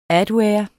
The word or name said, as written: adware